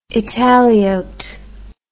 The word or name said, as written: italiote